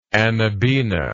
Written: anabaena

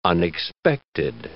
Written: unexpected

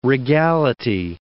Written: regality

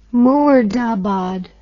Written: murdabad

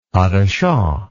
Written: pas de chat

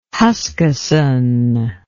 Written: huskisson

